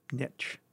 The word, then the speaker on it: niche: He's found his N-I-C-H-E. – Niche. – You knew that. I didn't know that. I thought it was niche. – I'm sure I pronounced it niche too, but niche is the preferred. Although niche is increasingly accepted, mm-hmm. niche is the older and more popular way to refer to a specialized place or role.